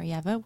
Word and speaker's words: Ieva: Well, my name is already complicated to spell, but I'm on Instagram at We Are Yeva, which is spelled We Are. I-E-V-A.